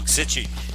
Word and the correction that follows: Sichting: Schiesting. S-I-C-H-T-I-N-G.